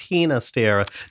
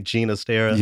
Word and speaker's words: Ginastera: I used to argue with the folks on email so much about that because they would tell me, well, actually, it's Ginastera. Do you have guidance from your radio profession on why we say Ginastera? – Yes, because he preferred the Catalan pronunciation of his name.